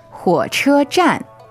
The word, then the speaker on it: 火车站: 火车站 So third tone, first tone, and fourth tone. – That's right. – 火车站 All right, and if this is your first time learning this word, don't worry, we're going to translate everything.